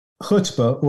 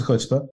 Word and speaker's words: chutzpah: The word chutzpah, or you can pronounce it chutzpah, chutzpah again. There's I'm not going to say anybody's wrong. I just pronounce it chutzpah, has 8 letters in it.